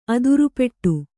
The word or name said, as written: ಅದುರುಪೆಟ್ಟು